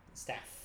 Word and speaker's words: Stapp: The P silent P? – Stapp. – Stapp. – Like staph infection. – Stapp. – Except not like a staph infection.